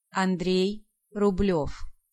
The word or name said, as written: Rublev